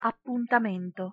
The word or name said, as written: appuntamento